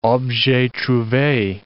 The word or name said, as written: objet trouve